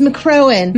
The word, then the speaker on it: McCrohan: Oh, it's McCrohan.